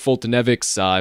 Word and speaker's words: Foltynewicz: But for the starters, you got Mike Foltynewicz, if I am pronouncing that right at this point.